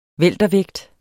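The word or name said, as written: weltervægt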